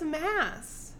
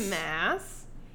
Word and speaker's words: Maas: And it really is Mass. Sarah J. Mass, Helion, Manon.